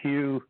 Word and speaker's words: Hugh: So if you put my name, it's Hugh, H-U-G-H, Traulson is spelled T like Tom, R-A-U-L-S-E-N like Nancy on YouTube.